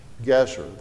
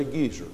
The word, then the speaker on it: Gezer: But boundary stones that kind of marked out the boundaries of the city, and it has the Hebrew words written there, Gezer, the consonants for the name of the city. Don't say Gezer. It's Gezer. Gezer is an old man. But Gezer is the name of the city.